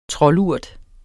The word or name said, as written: troldurt